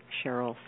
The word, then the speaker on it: Sherrill: I always like to invite people to go to my website, which is Dr. Cheryl Selman, and I'm going to spell that, S-H-E-R-R-I-L-L, Selman, S-E-L-L-M-A-N.